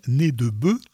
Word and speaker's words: nez de bœuf: Mais pour un Français ou un Québécois, un nez de bœuf, à prononcer comme au pluriel, nez de bœuf,